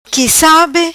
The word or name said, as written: Qui Sabe